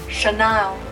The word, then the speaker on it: Chanel: Chanel, Chanel, Chanel。注意一下香奈儿的发音还有它的拼写，不是 double N，而是就一个 N。 OK，Chanel。 So actually, Coco Chanel isn't her real name，不是她的真名。她的真名其实也非常好听，叫做 Gabrielle, Gabrielle, Gabrielle, Gabrielle Chanel。